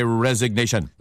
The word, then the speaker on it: resignation: So a very formal expression is I formally tender my resignation. – 네, R-E-S-I-G-N까지가 resign이 사임하다고요. 그게 A-T-I-O-N이 붙으면 발음이 resignation처럼 바뀌어요.